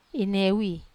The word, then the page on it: newi: wamesa